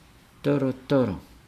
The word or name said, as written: dolodolo